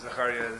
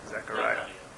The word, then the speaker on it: Zechariah: Could you phonetically spell Zechariah so I could... – Zechariah, Z-E, like Zechariah. Z-E-C-H-A-R-I-A. – Okay, Zechariah.